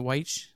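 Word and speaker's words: Wyche: Is it Weich? How do you say it? – Yeah, that's how you say it.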